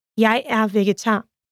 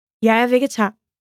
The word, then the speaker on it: Jeg er vegetar: In Danish, I am a vegetarian is Jeg er vegetar. Let's break it down by syllable. Jeg er vegetar. Now let's hear it once again. Jeg er vegetar.